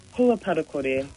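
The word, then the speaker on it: Hua Parakore: Now, what is, and I'm going to mispronounce it, Huapakori, is that what I'm hearing? – Huapatakori. – Patakori, so, okay, yep.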